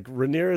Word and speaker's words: Rhaenyra: I I was telling Jude, it's funny that like the actress who plays Rhaenyra, did I pronounce that right? Rhaenyra? – Yes. Yeah, you got it right.